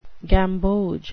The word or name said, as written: gamboge